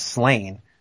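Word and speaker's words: Sláine: Is that the way you pronounce it? Slain? – I always have. Why would you think? – Yeah, it was? – It makes sense. Slain.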